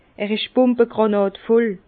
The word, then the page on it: er isch bumbagrànàdvoll: Bas-Rhin; Herrlisheim